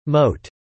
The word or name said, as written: moat